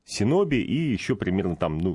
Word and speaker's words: синоби: Ниндзя — это синоби.